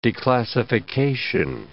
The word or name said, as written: declassification